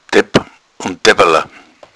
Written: depp

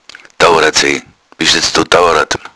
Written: douarad

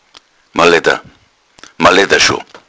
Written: maledda

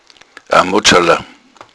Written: modschala

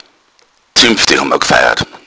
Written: zuempfde

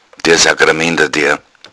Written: sacramenter

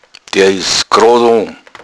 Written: grodo

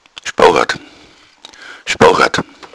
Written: spouhadd

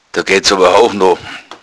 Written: houchno

